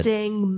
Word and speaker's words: Deng: D-E-N-G. – Dang. – Dang Maybior. M-A-B-I-O-R. – M-A-B-I-O-R.